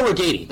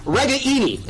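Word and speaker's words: Ragaini: We've been saying his name wrong for 4 years, and his dad's mad about it. It's an Italian name. We got to get it right. So it's Reganey, and we got to get that right.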